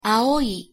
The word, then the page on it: aoi: japonais